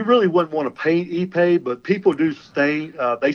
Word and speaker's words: ipe: That's spelled I-P-E. It's pronounced I-P-E. ePay, yes.